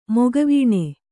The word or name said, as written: ಮುಖವೇಣು